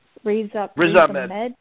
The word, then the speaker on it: Ahmed: Amen. – Riz Ahmed.